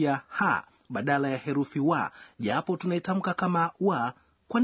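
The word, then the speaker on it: wa (は): Anasema, katika siku za nyuma, wa inayoonyesha mada ilitamkwa na kuandikwa kama ha. Matamshi yake yamebadilika pole pole na kuwa wa, lakini bado inaandikwa kama ha.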